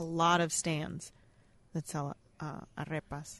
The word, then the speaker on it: arepa: A Venezuelan inspired now you've got to tell me how to pronounce this. Arepa or arepa? – It'd be shredded pork, A-R-E-P-A. – It'd be arepas. – Arepas? – Yeah.